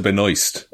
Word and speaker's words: Benoist: I think it's actually Melissa Benoist. Benoist, okay. – But everyone tries to make it too fancy.